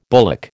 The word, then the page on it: bullock: American English